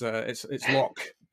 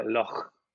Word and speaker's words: Loch: Yeah, it was, I just felt that the in your name was going to play into my roots, because obviously nobody in the world can pronounce Loch like a Scots person. You know, it's Loch, isn't it, for the vast majority? – Yeah, let me give it a try, Loch.